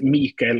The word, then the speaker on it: Mikael: How do you pronounce his name, like correctly? – It's a hard switch in between. That's why you can hear me try to say the Swedish words in English. Yeah. But it's Mikael Åkerfeldt.